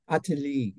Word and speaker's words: Attali: Attali is what Google told us.